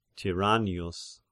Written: Tyrannius

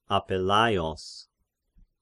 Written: Apellaios

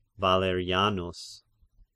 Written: Valerianus

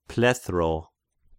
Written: plethras

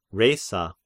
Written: Rhesa

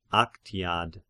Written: Aktiad

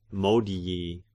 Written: modii

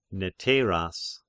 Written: Neteiras

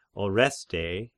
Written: Orestae

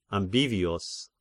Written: Ambivius

